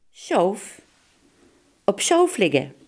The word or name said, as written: doodsbed